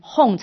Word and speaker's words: Hum: 一看呢，说你念什么，他说 Om Mani Padme Hum，他就念成牛。他说啊，不对，不是这样子念，你要念成 Hum 才对，不是牛。